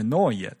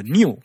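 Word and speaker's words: Neue: 那当时梁海明明知道那个字是 noy，但是呢，他按那个意思念成了 new，所以呢，这是按意思去套嘛，所以呢，new 是 noy 的训读，他想说的是这个意思。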